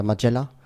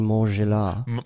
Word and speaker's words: Margiela: Ich bin erstmal beruhigt, dass du es richtig ausgesprochen hast. Viele sagen Maison Margiela oder Magella. Nein, das ist Maison Margiela.